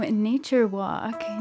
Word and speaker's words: Pnature Walk: So, Pnature Walk, your band name has a silent P at the beginning. So, what's that about? – Don't let it startle you. I mean, it's pretty quiet. It's like pneumonia, and I don't know.